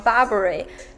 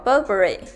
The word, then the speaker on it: Burberry: 那首先呢，第一个环节我们也是来说一下它的读音，就很多人都会习惯于把它读成 Burberry，这跟它的中文名翻译过来是巴宝莉也有关系。对，但是其实它的名字是读作 Burberry。